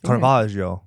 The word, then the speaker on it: Caravaggio: He was inspired by Caravaggio's paintings. Did I say that correctly? – Caravaggio.